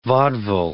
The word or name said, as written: vaudeville